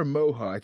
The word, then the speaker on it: Moja: And then the other one is it Moja or Moha? It's M-O-J-A.